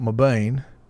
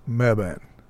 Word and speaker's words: Mebane: And Meb, like Webb. Okay, it's not Mabane. Pronounce Meb. Mebane. Mebane. Is that the way you say it? Mebane? Meb, like Webb. Okay. All right. And like this and that. Okay, Webban. Mebane. All right, Mebane.